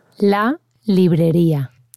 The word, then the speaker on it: La Librería: La Librería.